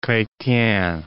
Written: Chretien

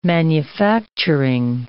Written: manufacturing

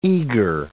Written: eager

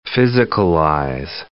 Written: physicalize

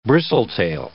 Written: bristletail

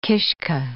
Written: kishka

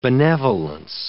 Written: benevolence

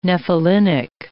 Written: nephelinic

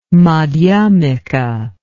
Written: madhyamika

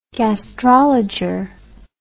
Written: gastrologer

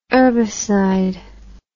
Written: urbicide

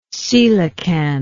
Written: coelacanthine

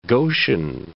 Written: Goshen

